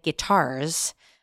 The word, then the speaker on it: Guittard: It's guitar with a D. – Yes. Yeah. – Guitar. – Guitar. Got it. Or if you're French, you don't pronounce the D.